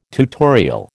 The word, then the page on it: tutorial: American English